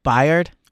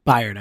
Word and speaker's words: Byard: Byard? – Byard, I believe. – Byard. – I believe so. – Okay. So he is... – Could be Bird or... It's B-Y-A-R-D. – I think it's B-Yard.